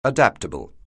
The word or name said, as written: adaptable